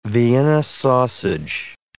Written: Vienna sausage

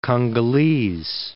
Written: Congolese

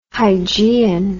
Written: hygieian

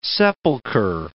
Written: sepulcher

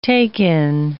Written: take-in

